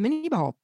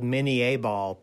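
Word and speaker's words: Minié ball: They called it mini ball, but technically it was probably called mini A ball because the inventor of it, Claude Minier, invented the ball.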